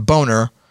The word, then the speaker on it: Boehner: And I took that name, Boehner, from John Boehner because I think it's hilarious. It looks like it's spelled Boehner. – Oh, totally. – And he pronounces it Boehner.